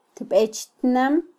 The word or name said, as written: tbechtnam